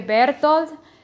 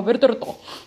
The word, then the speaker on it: Bertolt: En español vendría a ser algo así como que Bertolt, en inglés Bertolt, y en japonés que lo pronuncie como Bertorto. Bertolt.